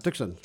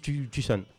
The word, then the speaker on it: Tucson: Tucson, c'est ça, Tucson. – Ils disent Tucson. – Tucson. – Tu prononces pas le C.